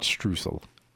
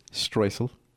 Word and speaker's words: streusel: I just wanted to let everybody know that Millie Martin has called in to remind me that the proper pronunciation is not streusel, it's streusel.